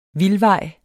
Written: vildvej

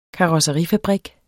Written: karosseri

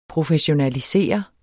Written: professionalisere

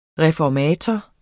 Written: reformator

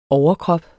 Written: overkrop

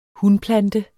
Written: hunplante

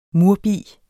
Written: murbi

